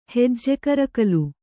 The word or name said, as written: ಹೆಜ್ಜೆಕರಕಲು